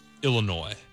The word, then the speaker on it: Illinois: It's pronounced Illinois. You don't pronounce the S, even in a soft way.